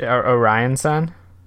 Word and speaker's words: Orion Sun: Is it Orion Sun? It's got to be this. Yeah. – Yeah, that's how you pronounce that.